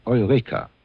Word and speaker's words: Eureka: Nun, Eureka, in deutscher Aussprache Eureka, ist das griechische Ich hab's gefunden, der Silber nämlich.